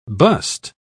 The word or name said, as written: bust